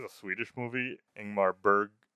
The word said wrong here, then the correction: Ingmar Bergman: I can never say his name. Ingmar Bergman.